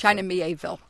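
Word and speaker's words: China Miéville: China Mieville.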